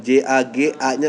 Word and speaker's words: jagan: meskipun gue masih coba sih yang kayak yang baru-baru tuh yang gue suka kayak ini bacanya jagan apa jangan sih ja, ya jagan J A G A nya enam hmm? N jagan jadi itu cerita tentang uh, satu hari manusia tuh ada hujan katak gitu uh -huh.